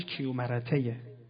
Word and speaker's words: کیومرث: اصلا این وقتی میگفتم خدمتون کیومرث، در ادب فارسی کلمه اسم کیومرث رو از می، این تلفظ درست پهلویش کیومرتهه، کیومرته یعنی زنده نامیرا، یعنی زندهی که نمیمیره، ولی که مرد بنده خدا، اما اسمش کیومرث.